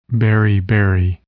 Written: beriberi

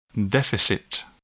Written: deficit

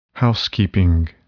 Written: housekeeping

